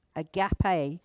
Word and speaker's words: agape: Agape, depending on how you want to pronounce it, which is a Greek word for love. Agape is how I say it.